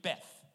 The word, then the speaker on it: Beth: B-E-T. Pronounced with an H in it. So it's really Beth.